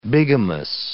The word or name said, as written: bigamous